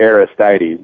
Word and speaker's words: Aristides: Is it the Aristides or the Aristides? – I pronounce it Aristides, but of course you and I are both upstate New Yorkers, so maybe that has something to do with the reason why we say it that way.